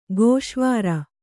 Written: ಗೋಷ್ವಾರೆ

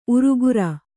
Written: ಊರುಕೇರಿ

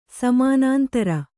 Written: ಸಮಾನಾಂತರ

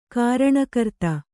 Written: ಕಾರಣಕರ್ತ

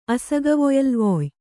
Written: ಅಸಗವೊಯಲ್ವೊಯ್

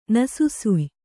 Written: ನಸುಸುಯ್

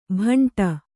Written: ಭಂಟ

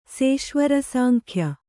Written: ಸೇಶ್ವರಸಾಂಖ್ಯ

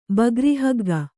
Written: ಬಗ್ರಿಹಗ್ಗ

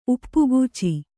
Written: ಉಪ್ಪಗಚಿ

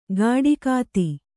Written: ಗಾಡಿಕಾತಿ

